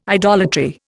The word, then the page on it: idolatry: Indian